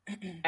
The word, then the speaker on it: Laundrie: Laundrie, and she was with him.